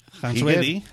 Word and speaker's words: Hansruedi: O sea, Heidi, el tío que estamos hablando hoy, que no sé cómo se pronuncia, tú has dicho Ready. Hans Ready, sí. Hans Ready, Federer, Heidi, ya está.